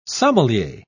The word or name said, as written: sommelier